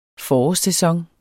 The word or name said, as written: forårssæson